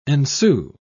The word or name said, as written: ensue